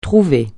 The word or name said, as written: trouver